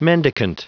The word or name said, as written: mendicant